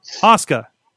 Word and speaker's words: Asuka: I'm not getting Asuka right. Asuka. Okay. Asuka. – And Finn Balor. – Listen, I'm still. No, the reason I messed up with Asuka is there was a character in Street Fighter that had the same name, and I've been mispronouncing it for like 20 years.